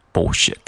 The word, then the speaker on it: bullshit: bullshit，发音我们也需要注意一下，就是这个 bull o 中间会有一个 l 的音，需要我们把舌尖顶到上牙床。